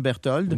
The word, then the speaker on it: Berthold: C'est comme ça qu'on le prononce, hein, Berthold? – Oui, c'est ça.